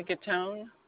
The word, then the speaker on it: Gattone: My name is Donna Gatone. – Okay. Please spell that last name. – G-A-T-T-O-N-E.